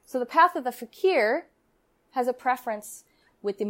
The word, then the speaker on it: fakir: Well, that's how you pronounce that word. I thought it was faker. Yeah, well, fakir is a Middle Eastern term.